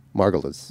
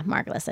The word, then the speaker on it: Margulis: Margulis. – Yeah, Margulis.